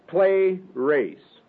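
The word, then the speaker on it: pleres: The third word of the vocabulary, play race. And that is spelled P-L-E-R-E-S. P-L-E-R-E-S.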